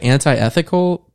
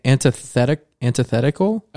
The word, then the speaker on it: antithetical: It looks like anti-ethical, but it's antithetical.